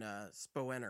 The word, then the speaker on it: Spooner: I believe the pronunciation on those are Strame and Spoener.